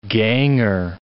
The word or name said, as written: ganger